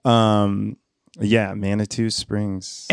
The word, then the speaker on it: Manitou Springs: It's pronounced Manitou Springs, actually. Manitou Springs.